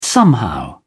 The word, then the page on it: somehow: British English